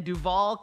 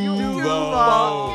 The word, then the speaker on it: Duval: Steve, this one is from Miracle in Duval County. – You don't say it like that, Sheryl. Come on. – I know. I was waiting for you Duval. – Guys. – Duval.